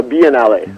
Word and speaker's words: Biennale: Is that how you pronounce it? – Yeah, the Biennale.